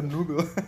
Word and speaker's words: Nobel: Dependendo da sua Nubel. vertente. É. – Se você for o Leôncio. – Se você, se você pronunciar certo, é Nobel.